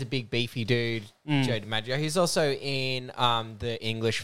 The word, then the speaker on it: DiMaggio: You say John Mingolo. – John I'm pronouncing everything wrong. – DiMaggio.